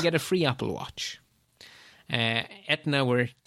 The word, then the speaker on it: Aetna: American health insurer, Aetna, I think is how you pronounce it. It's not how you spell it, but I'm going to pronounce it as Aetna. – Aetna is correct.